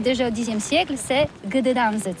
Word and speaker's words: Gdańsk: Ça s'écrit G-D-A-N-S-K. On dit Gdansk ou Dansk, on prononce le G ou pas? – Oui, c'est pas très facile pour des étrangers, mais remarque bien que la langue polonaise, c'est la langue où on prononce chaque lettre qu'on écrit. C'est pourquoi on dit Gdansk.